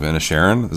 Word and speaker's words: Inisherin: I caught up with Banshees of Inisherin. Is that how you say it? – Yep.